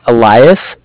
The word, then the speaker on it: Elias: So my name's Elias, E-L-I-A-S. – Okay. – And I'm calling from Bank of Cardiff. Cardiff is spelled C-A-R-D-I-F-F, as in Frank.